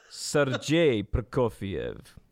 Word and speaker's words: Sergei Prokofiev: and I have now the correct pronunciation. – I was given one as well. – Sergei Prokofiev.